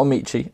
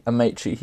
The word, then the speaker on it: Amaechi: So for episode four, uh, we managed to get the main man, John Amaechi, not Amaechi, Amaechi.